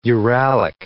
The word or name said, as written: Uralic